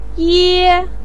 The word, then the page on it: ye: 1